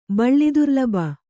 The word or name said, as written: ಬಳ್ಳಿದುರ್ಲಬ